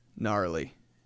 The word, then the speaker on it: gnarly: Gnarly. Always kind of a cool word until you see how it's spelled with the G.